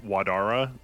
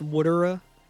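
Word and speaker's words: Watera: Wadara? Waterara? – Yeah, Wadara. And that's actually pronounced Woodara.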